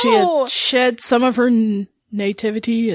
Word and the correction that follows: naivete: and naivete. – I knew I'd say that wrong.